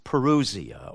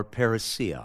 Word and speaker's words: parousia: What will be the sign of your, now that word, and we've had this, you remember, when we were in Thessalonians, it's parousia or parousia, however you want to pronounce it, and the end of the age, your coming.